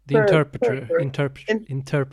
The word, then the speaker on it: The Interpreter: Imperpeter? Tolk, vad heter det? – Ja. – The Interpreter.